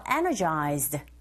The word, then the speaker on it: energized: 첫 번째 빈칸에 feel energized 발음하실 때 이 단어의 강세의 위치가 어디 있는지 정확히 아셔야 돼요. energized가 아니고 첫 번째 강조해줘서 I feel energized 이렇게 발음하시면 됩니다.